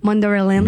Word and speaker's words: Mandalorian: Mandalorian, comment tu dis ça? – Mandalorian.